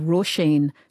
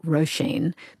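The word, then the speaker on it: Róisín: And I'm saying Roisin, not Roisin, because she is originally from Belfast, and that's how they pronounce it up there.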